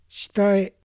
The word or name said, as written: Shitae